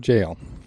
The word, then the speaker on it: gaol: Williamsburg jail. – Yes. Pronounced jail. – I like it. – G-A-O-L.